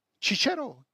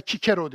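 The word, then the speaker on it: Cicero: Çiçero, aslında Kikero diye okunur doğrusu bu. Çünkü Romalı onu Kikero derdi.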